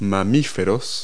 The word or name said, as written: mammals